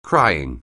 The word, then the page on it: crying: anglais